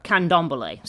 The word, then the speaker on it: Candomblé: Um, I want to say Cambole, but I don't remember if I'm saying if I'm missing a um, what you call it. Um, uh, what is it? Uh, candombole. Sorry, I knew I was I knew I was missing a syllable somewhere. Candombole.